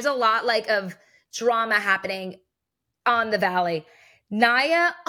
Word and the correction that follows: Nia: am I saying her name right? – It's Nia.